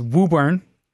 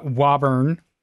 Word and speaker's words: Woburn: But it's, anybody reading the press release, it's Woburn. It's not Woburn. It's Woburn. Okay. You got to say it right.